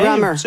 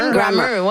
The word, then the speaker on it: Grammar: En l'écoute de Bonne nuit, chérie et Soleil secret, j'ai pensé à un groupe qui s'appelle London Grammar. Je prononce bien? – Grammar. – Grammar, oui.